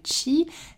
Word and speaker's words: qi: À présent, je vais passer à notre deuxième trésor, le chi. Ça s'écrit qui hein, q-i, mais ça se prononce donc chi.